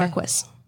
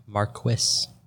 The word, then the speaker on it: Marquis: And yes, that is how you pronounce it. I looked it up. The 5th Marquis of Angsley in the case of the missing jewels. – Are we sure it's not Marquis? – I specifically thought it might be Marquis, but it's spelled different. So I looked up the pronunciation specifically. It is okay. Marquis.